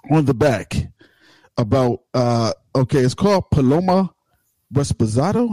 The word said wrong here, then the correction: Reposado: Am I saying that right? – That's close enough. – Reposado.